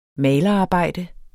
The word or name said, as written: malerarbejde